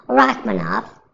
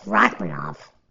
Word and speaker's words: Rakhmonov: On my chat, job cut, which I found this out, is actually Rakhmonov because I do pronounce the K there. They do. It's actually the pronounce the H first and then the K. Where Dagestan will not pronounce the K whatsoever, they'll just skip it. So go. Yeah, cool. It's Rakhmonov. But yeah, yeah, that's it.